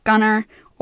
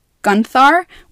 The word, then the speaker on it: Gunthar: Gudrun had three other siblings, and one of their names is Gunthar, or Gunnar, or it's pronounced, it's, it's worded out in different ways, but the version that I heard was Gunthar, which is exactly how they have it spelled in Hosab.